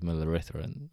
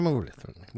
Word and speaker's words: Muralitharan: I thought before you gave me the options, it was Muralitharan. Oh, man, I butchered that. We go with B. – Yes! Mattia Muralitharan.